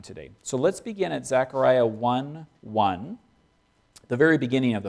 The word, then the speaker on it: Zechariah: We're looking at selected verses from Zechariah, or as a rabbi corrected me, it's often pronounced Zechariah.